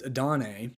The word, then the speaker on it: Adonai: So Georgia had a receiver whose name is Adane, but it's spelled, you might have seen this guy, it's spelled Adonai. So, you know, A-D-O-N-A-I. That is Adonai,